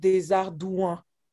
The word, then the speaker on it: Desardouin: So Desardouin, like the trick here is like, so the O-U-I-N at the end, O-U sounds like OU, and then I-N is one sound as well, and it sounds like UN. So it's a sound that's very nasal. Mm-hmm. So the whole world together, Desardouin.